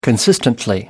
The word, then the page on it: consistently: British English